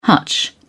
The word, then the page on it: hutch: British English